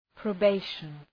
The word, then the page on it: probation: prəʋ'beıʃən